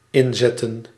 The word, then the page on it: mettre: [mɛtʁ]; France